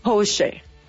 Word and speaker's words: Porsche: Y solo como un dato cultural, déjenme les cuento que es alemana y su correcta pronunciación es, escuchen, Porsche.